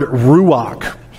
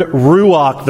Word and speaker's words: ruach: Now, if you're going to say that Hebrew word ruach the right way, you've got to say it like there's a piece of popcorn stuck in the back of your throat. Ruach, right?